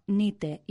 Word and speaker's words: Knight: Si se atendiera a la pronunciación real, no se escribiría en inglés Knight, K-N-I-G-H-T, ni sword, S-W-O-R-D, sino nite y sword.